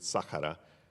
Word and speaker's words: Sahara: We've ministered in jungles and war zones in the correct pronunciation, Sahara. To say Sahara Desert is a redundancy because Sahara is Arabic for desert, so in the Sahara.